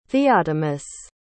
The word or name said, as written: theotimus